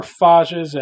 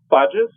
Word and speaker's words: phages: What are phages and what makes them attractive for use to combat bacteria? – Sure. So phages, or phages, either pronunciation is correct, are bacterial viruses.